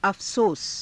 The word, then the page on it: afasosa: Hindi